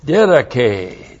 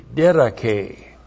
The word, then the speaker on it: didache: Didache, D-I-D-A-C-H, long E, didache.